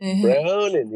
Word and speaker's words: Browning: Yeah, Browning. You got to say it like that.